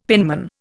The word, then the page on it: penman: Indian